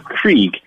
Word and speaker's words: kriek: But a creek, this style of beer, K-R-I-E-K is how it's spelled.